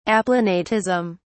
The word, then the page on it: aplanatism: British English